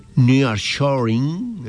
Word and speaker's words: nearshoring: El foro busca fortalecer las relaciones económicas entre Alemania y América Latina, impulsar inversiones y debatir oportunidades ligadas al nearshoring, ¿lo dije bien? – Sí, bueno, muy bien.